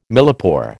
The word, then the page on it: millepore: American English